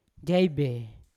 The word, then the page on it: jaibe: Yawa